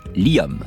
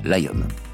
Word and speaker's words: Liam: Oui, les Anglais prononcent Liam et non pas Liam.